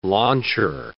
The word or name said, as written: launcher